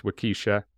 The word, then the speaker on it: Waukesha: In 2014, Lautner was a sixth grader in, I think it's pronounced Waukesha. It looks like that's how it's spelt, so I'm going to go with that's how it's actually said. Wisconsin, when Morgan Geyser and Anissa Weir attacked her after a slumber party at Geyser's home.